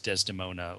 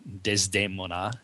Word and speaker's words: Desdemona: And Renee Fleming, one of the Met's biggest stars, was Desdemona, or Desdemona, as it's pronounced in the opera.